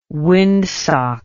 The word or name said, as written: windsock